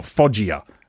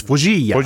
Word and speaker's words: Foggia: Because we get the broadcast with these fucking idiot English commentators, and they just go, oh, Foggia. That's not his fucking name. It's Foggia. She said it properly.